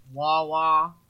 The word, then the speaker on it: Wawa: When I go to like CVS or like Wawa, the only 2 places.